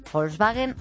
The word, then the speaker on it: Volkswagen: Y El País: Volkswagen admite fraude en 11 millones de coches. – Estás pronunciando muy bien Volkswagen, porque a mí en el colegio me lo hicieron repetir 30.000 veces.